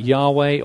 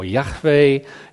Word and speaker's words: Yahweh: Some scholars talk about Yahweh or Yahweh. In the past, it's been pronounced Jehovah. You can see that just about Jehovah, which actually isn't a very accurate way of saying it.